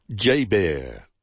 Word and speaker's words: JBER: And the base is commonly known by the acronym J-B-E-R, pronounced J-Bear.